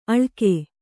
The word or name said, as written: ಅಳಗೆ